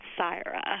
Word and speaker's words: Sirah: It has an I in it, but it also has an H. – Oh, so I was right. S-I-R-A-H? – That's correct.